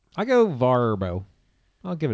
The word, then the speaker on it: Vrbo: We'll be using Vrbo Is that Vrbo? – Is it pronounced Vrbo? – Yes.